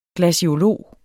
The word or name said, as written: glaciolog